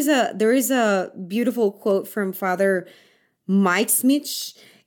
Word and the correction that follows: Mike Schmitz: and he, it's, Mike Schmitz. It's, yeah, yeah, Mike Schmitz.